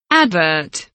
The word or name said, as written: advert